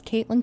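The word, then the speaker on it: Kaitlyn: Yeah, so my name is Caitlin Kohler, so I'm gonna go ahead and spell it, because there's like a million different ways to spell Caitlin.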